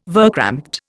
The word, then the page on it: verkrampte: Indian